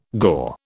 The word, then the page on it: gaur: British English